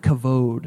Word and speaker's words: kavod: It's a word, you pronounce it kavod. Can you say kavod? Kavod.